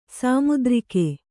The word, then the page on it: ಸಾಮುದ್ರಿಕ: Kannada